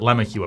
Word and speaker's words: LeMahieu: L-E, capital M-A-H-I-E-U. – I always mispronounce it. I always call him LeMahieu because that's how it seems like it should be pronounced because it's French. – Right, and that would make the capital M more obvious. – Yeah, but he pronounces it LeMahieu apparently. – Yeah, and there's Glenn Grothman. Glenn is spelled G-L-E-N-N.